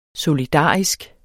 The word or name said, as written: solidarisk